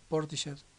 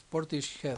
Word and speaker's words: Portishead: Que no se dice así. La vez pasada un inglés me corrigió. Es Portishead. – Portishead. – Algo así. – Portishead. Los anglos dirían Portishead.